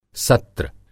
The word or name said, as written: सत्र